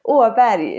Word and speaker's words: Åberg: Åberg is my name in Swedish, like last name.